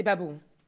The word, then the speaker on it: Gbagbohon: Je m'appelle Maureen, mais mon deuxième prénom qui est écrit sur ma carte d'identité, c'est Babohon. G-B-A-G-B-O-H-O-N. Il commence par un G qui se prononce pas. D'ailleurs, tout le prénom est imprononçable. Babohon.